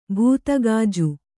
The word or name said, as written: ಭೂತಗಾಜು